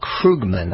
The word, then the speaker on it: Krugman: Paul Krugman, I should say, that's the way he pronounces his name.